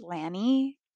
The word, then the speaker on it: LANY: I found out that one of my favorite bands that I was calling Lonnie, L-A-N-Y, if you were like, we know that's not how it's pronounced. Like, I'm sorry, I've literally been calling them Lonnie for years. Like, such a great, just like, belt your heart out type of vibe. And they're actually pronounced Lanny.